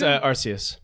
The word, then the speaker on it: Arceus: It's Arceus.